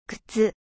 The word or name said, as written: chaussures